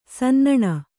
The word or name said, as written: ಸನ್ನಣ